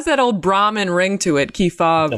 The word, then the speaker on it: Kefauver: which you have a chapter about Senator Kefauver.